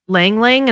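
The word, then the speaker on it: ylang-ylang: There's one that's called like Lang Lang and it's spelled like Y-L-A-N-G.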